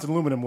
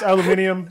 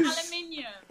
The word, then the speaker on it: aluminium: That and why do you pronounce aluminum weird? I guess maybe we announce it. Maybe we say it weird. I know it's aluminium. – Say it weird. – Holy aluminium.